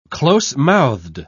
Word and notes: closemouthed: British English

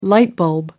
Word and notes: light bulb: American English

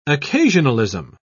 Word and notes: occasionalism: British English

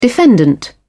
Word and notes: defendant: British English

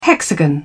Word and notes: hexagon: British English

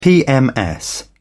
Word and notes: pms: British English

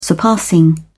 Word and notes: surpassing: British English

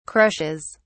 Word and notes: crushes: British English

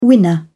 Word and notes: winner: British English

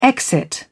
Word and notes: exit: British English

